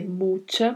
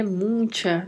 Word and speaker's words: mucha: A pronúncia da palavra, a gente tem que ter bastante cuidado aí com CH, que tem o som que falamos na palavra tchau, tá? Pronunciamos com este som, que é mucha, mucho. E muita atenção para não querer colocar um N aí que não existe, tá? Já ouvi muito pessoas pronunciando a palavra mucha, mucho, e falando mucho, né? Mucha, né? E não é assim, não existe um N aí. É mucha e mucho.